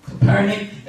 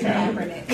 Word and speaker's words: Kaepernick: But I know about, you know, what's happening with, you know, Kaepernick. That's how you say it. – Kaepernick.